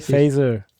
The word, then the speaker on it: Faeser: So spricht man die sonst aus? Nancy Faeser.